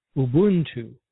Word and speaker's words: Ubuntu: If you listen to how most African words are pronounced, it would just make sense that it would be pronounced Ubuntu.